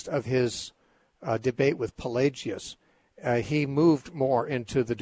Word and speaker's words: Pelagius: Pelagius, yeah.